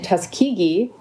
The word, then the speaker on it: Tuskegee: So she was born in Tuskegee, Alabama. – Hmm. – So I do know how to pronounce that now, thanks to Night at the Museum.